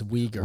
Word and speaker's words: Uyghur: Oh, no, he said Wigger. – W bomb. And that's W-bomb. Muslim in China. – That's Uyghur. – Uyghur. Oh, and if you don't know, now you know. – Mispronounced it.